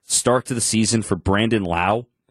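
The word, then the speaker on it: Brandon Lowe: Remember, it's Brandon Lau and Josh Lowe. Spelt the name, spelt the same, pronounced differently.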